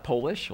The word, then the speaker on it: Polis: My last name is not Polish, although it rhymes with Polish. Polis is not Polish, it's Greek.